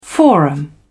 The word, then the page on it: forum: British English